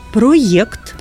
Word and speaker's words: проєкт: Щодо змін в правописі, слід зазначити, що по-новому пишемо і вимовляємо слова «проєкт», «проєкція». Автори пояснюють, що тут є латинський корінь, який і в словах «об'єкт», «ін'єкція». Тому запам'ятаємо «проєкт» і «проєкція». Вже пишемо і вимовляємо з буквою «є», а не з звичною «е».